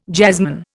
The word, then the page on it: jazzman: Indian